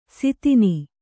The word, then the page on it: ಸಿತಿನಿ: sitini